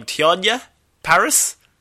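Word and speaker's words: Teyonah Parris: Tiana Paris? – Am I pronouncing that correct? – Yeah, who was first introduced as Monica Rambeau in WandaVision...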